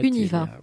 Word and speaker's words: Univa: Ou alors sur notre site, je, dis une bêtise, puis sur, le site, euh, internet, Univa. voilà. Comme ça se prononce.